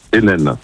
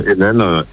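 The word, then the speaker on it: Henein: Vincent Hennen, avec un H, H-E-N-E-I-N. Ça se prononce comme Hennen, N-N, mais on peut l'écrire Heineken aussi.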